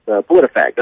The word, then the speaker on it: PolitiFact: It's PolitiFact. That's correct.